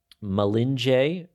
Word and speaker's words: Marinje: Makuma, Malinje, it's spelled with an R, but we pronounce our R's as an L. Marinje is how you would find it.